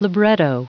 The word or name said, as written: libretto